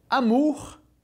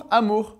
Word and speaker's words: amour: Então essas três palavras que servem de exemplo pro R pronunciado no final de palavras, de novo, bonjour, amour e au revoir. Fechou? Bonjour, amour, au revoir.